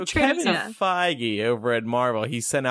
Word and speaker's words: Feige: Is it Feige? – Yeah. – Because that's how I've always pronounced it. And then one time I heard something else and... All right. – I'm sure it's... Yeah. – So it's Feige.